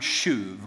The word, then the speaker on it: shuv: In the Hebrew, it's the word shuv, okay? So think of shove, but kind of a different pronunciation. So shuv, it's this word that really just means or demonstrates to turn.